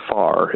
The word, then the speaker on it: Farrar: And then also the town name, basically far. It's just, you know, not close, but far. We don't know which AR is silent.